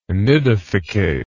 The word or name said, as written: nidificate